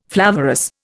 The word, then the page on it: flavorous: Indian